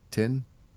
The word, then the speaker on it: thin: So, but the character's name was actually Tin Lizzy, but they changed it to refer to the Dublin accent's pronunciation of thin, which actually sounded like tin. – Interesting. – You know, because they don't really pronounce the H with that accent.